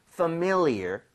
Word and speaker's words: familiar: 그래서 familiar보다는 familiar. 그래서 familiar. MI 쪽에 강세를 주고 한번 발음해 볼게요.